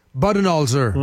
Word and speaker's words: Budenholzer: Comment tu le prononces? – Buden. – Budenholzer. – Ouais.